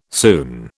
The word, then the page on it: soon: British English